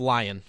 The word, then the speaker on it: Lyon: Text line's telling me it's pronounced Lion, so I'm going to go with that. I'm going to trust the text line here.